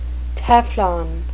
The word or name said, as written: teflon